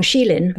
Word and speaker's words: Xilin: Did I say his name correctly? Wang Xilin. Xilin.